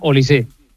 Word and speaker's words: Olise: No, es francés. Es francés. No lleva acento, pero se pronuncia Olise.